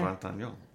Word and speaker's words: Trintignant: Trintignant.